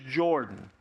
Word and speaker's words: Jordan: He would point it out that it was like the River Jordan in the Bible.